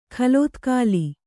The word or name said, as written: ಖಲೋತ್ಕಾಲಿ